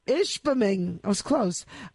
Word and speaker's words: Ishpeming: Yeah, I was going to say, I was really proud of you for pronouncing Ishpeming properly.